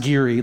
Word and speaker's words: Geary: you said Geary, which was reasonable, but it's pronounced Geary, like Siri.